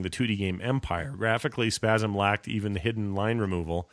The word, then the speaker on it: Spasim: It's spelled S-P-A-S-I-M, like Spasm. – Spasm? Anyway. – It's Spasm.